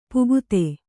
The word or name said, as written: ಪೋಕ್ತ